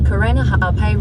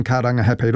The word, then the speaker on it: Karangahape: That was TVNZ's Māori Issues reporter Tianiwa Huri Hanganui driving around Auckland and unimpressed with the navigation voice on the Google Maps app mispronouncing Karangahape Road and other places with Māori names.